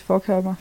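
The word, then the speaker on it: Forchhammer: Hvordan vil du udtale hans navn? Lukas For Forkhammer? Ja, faktisk Forkhammer. Forkhammer? Ja, det, det lyder lidt ubehageligt, men det tror jeg faktisk, det er. Ja. Han hedder Lukas Forkhammer.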